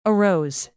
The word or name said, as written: arose